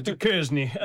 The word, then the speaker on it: Duchesne: Not Duchesne? – No, Duchesne. – It's pronounced Duchesne.